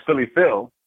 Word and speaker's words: Philly Phil: I am your host, Philly Phil.